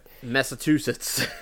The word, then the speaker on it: Massachusetts: Massa skjuter, nej, jag kan inte uttala det. – Massachusetts.